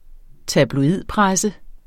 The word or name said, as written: tabloidpresse